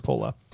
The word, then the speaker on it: Coppola: So this was directed by Francis Ford Coppola, and I'm going to mispronounce Coppola like five times, but it is, I'm pretty sure, Coppola. Supposed to be pronounced Coppola.